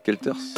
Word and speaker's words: Guelters: J'ai vu, il y a aussi Jean Gelters. Je prononce bien son nom, hein, ça va. Gelters. – Très bien, très bien.